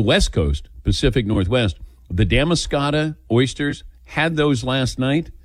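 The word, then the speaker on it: Damariscotta: Yes, Damascata is the correct pronunciation.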